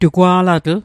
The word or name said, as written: tugwa'latl